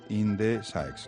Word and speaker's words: Indesaex: Belén Rivera Jiménez es gerente y administradora de Indesaex. ¿Lo he dicho bien, Belén? – Sí, innovación y desarrollo extremeño.